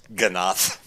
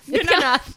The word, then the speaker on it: Ganas: It's Ganath. – Ganath. Only in Europe. – From the Castilian.